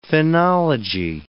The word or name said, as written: phenology